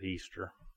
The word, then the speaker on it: Ishtar: I-S-H-T-A-R. Most people say Ishtar, but some pronounce it Easter.